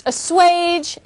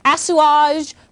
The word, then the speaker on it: assuage: And obviously, it is pronounced assuage and not assuage, but if you feel like commenting on that, go ahead.